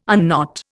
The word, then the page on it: unknot: Indian